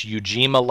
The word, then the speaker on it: Ujima: And help make sure I'm pronouncing this right, Stephanie. It's Ujima Legacy Fund. – Is that right? – Okay. – That's correct. And, yes.